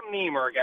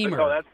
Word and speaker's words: Niemerg: Well, it's Adam Niemerg, actually. Niemerg.